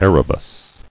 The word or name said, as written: Erebus